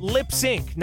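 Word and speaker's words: Lipps Inc.: Yeah, you call him Lip Sync, not Lips Incorporated. Lip Sync. Say it like that.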